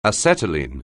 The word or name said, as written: acetylene